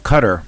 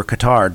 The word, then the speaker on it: Qatar: Recently, this week, the small nation of Qatar, or Qatar, depending on how you want to pronounce it, was isolated.